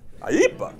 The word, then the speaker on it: IEEPA: The answer is a statute called the International Emergency Economic Powers Act, IEEPA. You have to say it like that, IEEPA.